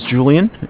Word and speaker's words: Julian: Okay, my name is Julian. It's J-U-L-I-A-N.